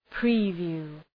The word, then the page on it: preview: ‘pri:,vju: